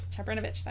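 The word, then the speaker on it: Čabrinović: Um, that one of the conspirators, um, Najelko um, Chab- Chabrinovich, Chabrinovich, that is how it's pronounced, um, was spotted in a crowd, lurking behind a tree near where Sophie was walking.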